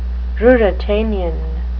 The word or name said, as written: ruritanian